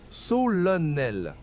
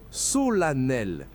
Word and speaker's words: solennel: Beaucoup disent solennel en ne prononçant qu'un seul N. La bonne prononciation, solennel. La première syllabe se prononce so, la deuxième la, et le double N allonge légèrement le son.